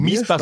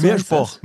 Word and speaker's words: Miesbach: Ja, also auch nicht Miesbach, sondern Mirschboch. Mirschboch. – Ja? Ah, okay, also mit R und SCH und O wird das ausgesprochen, ja? – Mirschboch.